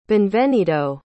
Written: beinvenido

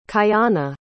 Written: kayana

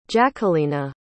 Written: jacquelina

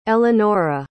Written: elenora

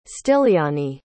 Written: styliani

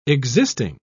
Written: existing

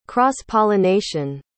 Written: cross-pollination

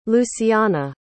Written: luciana

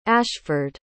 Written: ashford

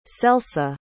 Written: celsa

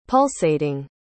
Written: pulsating

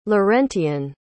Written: laurentian

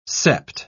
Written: sept

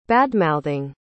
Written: badmouthing

